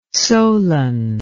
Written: solan

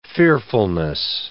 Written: fearfulness

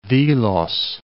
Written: Dhilos

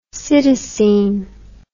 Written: cytisine

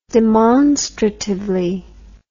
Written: demonstratively

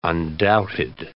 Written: undoubted

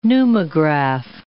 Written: pneumograph

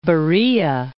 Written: Beroea

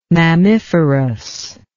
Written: mammiferous

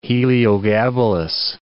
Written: Heliogabalus

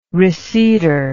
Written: receiptor

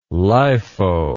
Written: lifo

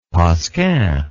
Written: pascin

